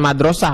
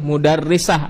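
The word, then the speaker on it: mudarrisah (مُدَرِّسَة): Hiya mudarrisah, nah ini jangan salah baca ya. Jangan madrasah, kalau madrasah sekolah. Ini mudarrisah, ada tasdid pengajar perempuan atau guru perempuan di sana, sebagai pengajar di sana. Sehingga bacanya bukan madrasah, kalau madrasah sekolah, mudarrisah, guru perempuan.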